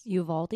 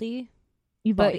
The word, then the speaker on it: Uvalde: Uvalde. – Uvalde.